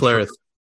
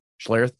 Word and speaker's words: Schlereth: Schlereth. – Schlereth.